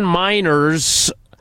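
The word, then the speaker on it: Meinerz: Per the Denver Broncos team website, Quinn Miners pronounces his name Miners. – Miners, not miners. Like children. Not that. Not coal mining.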